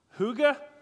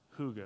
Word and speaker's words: hygge: Like the Danish word, hygge. Anybody know about hygge? It's popular nowadays. It's spelled H-Y-G-G-E.